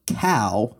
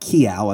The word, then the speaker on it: cow: In response to this, New England travelers would practice saying cow, right, not kiao.